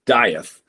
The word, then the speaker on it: Dieth: So actually the name as we pronounce it is Dieth. – Oh, it's right? Dieth? – Okay. Yeah. It's like Dieth, like you know, think like Hamlet, Shakespeare, right? – Okay.